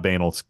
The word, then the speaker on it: banal: Yes, pronounced like anal, Sam. Actually, it's not. It's not banal. It's pronounced banal. I'm sorry. sorry to actually you like that, but it is pronounced banal.